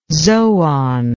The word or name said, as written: zoon